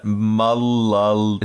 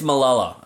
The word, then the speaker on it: Malala: Malal. Is that a T? Malal. – Nah, they're all L. It's Malala.